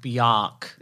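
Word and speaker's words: Björk: It's pronounced Bjork. – Is it? – Mm-hmm. – Is it? – No, it's pronounced Bjork. – What did I say? – You said Bjork. – It's Bjork. Bjork. Mm.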